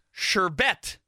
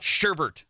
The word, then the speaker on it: sherbet: That nobody agrees with me on is that the ice cream is pronounced sherbet, not sherbert. – Yeah. – There is no R in the word, people.